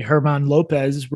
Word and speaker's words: German: His name is spelled German, Herman Lopez, he's honest.